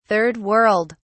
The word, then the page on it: Third World: British English